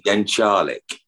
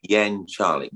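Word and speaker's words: Jeczalik: So that's you and Anne and JJ Jek Is that you pronounce it? – Yeah, uh, yeah. No, Jen Charlik. Jen Jen Charlik. Jen, Jen Charlik. J E Z in Polish is Jen.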